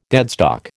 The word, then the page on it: deadstock: American English